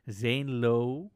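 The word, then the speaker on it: Zane Lowe: En die werd meteen internationaal opgepakt door een première in Amerika op de befaamde Zenlo. – Zeg ik dat goed? Ja, ja. ja.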